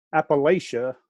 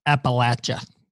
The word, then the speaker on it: Appalachia: So going from there to Appalachia was a culture adjustment. – Quite a bit different. Let me just say that. – Now, he mispronounced that word, by the way. It's Appalachia. Where we're from, it's Appalachia.